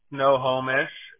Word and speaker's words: Snohomish: Snohomish, Snohomish.